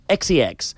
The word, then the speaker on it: XCX: No us confoneu perquè es pronuncia XCX. És una X, una C i una X.